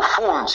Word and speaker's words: fun: fan vicioso, ¿no es cierto? En, traducido al español. – Eh, no, diversión graciosa. Ah, ah, en fan con U, fun sería.